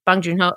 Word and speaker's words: Bong Joon-ho: Bong Joon-ho always, that's how it's pronounced. Bong Joon-ho.